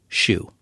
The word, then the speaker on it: SHU: There are over 1,000 isolation cells in its secure housing unit, which is known by its acronym SHU, pronounced as SHU.